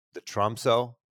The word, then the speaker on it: Tromsø: And I'm not going to try to pronounce, is it the Tromso? Is that the name of it? – You did a good job.